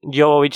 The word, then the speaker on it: Jovovich: que actúa esta Mila Jovovich, ¿se pronuncia? – Jovovich sí, pero algunos también la conocen como la cuarta fase quizás.